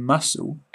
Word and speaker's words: muscle: In muscle, SC is S.